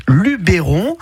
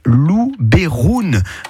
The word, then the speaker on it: Luberon: En provençal, en fait, le E se prononce E. Donc, si vous dites Luberon, ben, c'est presque la bonne prononciation d'origine. Voilà. Parce que la prononciation de base en provençal, c'est Luberoun. Voilà.